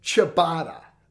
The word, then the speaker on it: ciabatta: But it's ciabatta. – Ciabatta. – I called it something else, butchered it. I mean, I think segment 3 last week, I think we were both feeling no pain at that point. – Yeah. – And I don't even know what I called it, but apparently I did not pronounce it ciabatta.